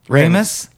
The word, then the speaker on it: Ramis: I need to know if you're being cute or if you think it's pronounced Ramey. – Oh, Ramis? – Yeah.